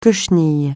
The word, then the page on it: cochenille: francais